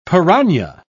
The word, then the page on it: pirana: British English